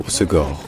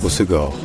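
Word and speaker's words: Hossegor: Dans l'accent du sud-ouest, donc localement, on prononce ça en trois syllabes, on dit Osegor. Osegor.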